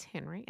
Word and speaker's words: Henrie: This is Henry, and thank you for tuning in and listening. I do spell my name H-E-N-R-I-E.